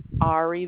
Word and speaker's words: Ari: Ari, that is spelled A-R-I. – A-R-I, and is there a confirmation number or something for this? – You'll receive an email.